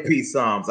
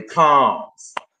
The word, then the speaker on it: Psalms: I don't say peace psalms. I say palms.